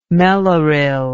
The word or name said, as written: mellaril